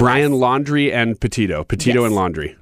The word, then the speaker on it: Petito: Petito.